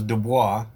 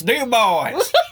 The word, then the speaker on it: Dubois: Wait, do you say the S? – Yeah. They're from Dubois? Wyoming, so they don't say it like the French say it. The French is Dubois. – Yeah. – And they're Wyoming. They're like, it's Dubois.